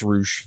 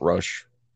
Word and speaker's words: Rush: Yes, it is pronounced Roosh, not Rush.